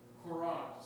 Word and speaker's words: Courant: And the name of the newspaper was the New England Courant, C-O-U-R-A-N-T, Courant, I think is the way you pronounce it.